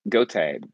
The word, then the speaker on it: Goethe: Yeah, Goethe. – Goethe. Okay. Thank you for that. I should have done that beforehand. – You're good. – Anyway, so this section quotes Goethe significantly, basically talking about where fact and theory coincide.